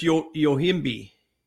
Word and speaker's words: yohimbe: Okay, that's funny that you said Yoimbo, and it's Yohimbe.